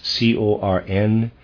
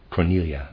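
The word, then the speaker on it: Corneille: Reader's aside, Cornelia is Cornelie. I'm pronouncing that Cornelia, I guess, sorry.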